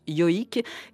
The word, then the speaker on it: joik: Ça s'écrit J-O-I-K, ça se prononce yoik.